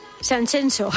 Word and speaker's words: Sanxenxo: y te cuento que este fin de semana en Sanxenxo, no Sanxenxo, como dicen... – Qué bien, ¿no? Lo pronuncias, Sanxenxo, ¿eh? Es como Shakira.